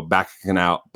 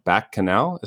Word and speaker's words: bacchanal: So speaking back of kitchen sink uh, filmmaking, so uh, the the opening forty five minutes with the giant, you know, back canal, bacchanal is how you say bacchanal.